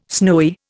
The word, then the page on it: snowy: Indian